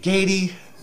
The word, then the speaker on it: Gade: A couple times after I told you that Gady? It's Gady. – Gady.